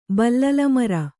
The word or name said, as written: ಬಲ್ಲಲಮರ